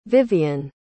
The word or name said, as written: viviane